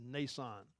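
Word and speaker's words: Mnason: And it is M-N together, but the M is silent, so it's really just Nason in the Greek.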